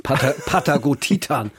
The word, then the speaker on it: Patagotitan: Also ich habe den ganz falsch ausgesprochen offenbar. Patagotitan.